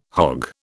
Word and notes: hogg: British English

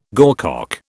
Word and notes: gorcock: British English

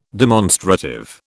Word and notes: demonstrative: British English

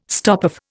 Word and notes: stopoff: Indian

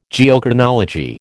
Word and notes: Geochronology: American English

